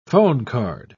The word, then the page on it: phonecard: British English